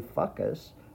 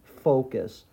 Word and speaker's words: focus: So the sound that we didn't want to make in the first syllable in focus, we didn't want to say fuckus, but this time it's cuss. So it's faux and then cuss.